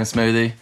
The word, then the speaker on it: Smoothy: Yeah, just Sam Smoothie. – At Sam Smoothie on Instagram? – Yeah. – That's where people S-M-O-O-T-H-Y, you know, slightly drinks, just not spelled the same.